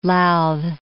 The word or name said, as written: Louth